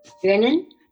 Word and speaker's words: Drinnon: Drinnon.